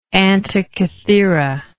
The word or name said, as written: antikythera